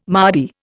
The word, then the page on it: mardy: Indian